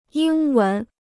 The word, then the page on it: 英文: yīng wén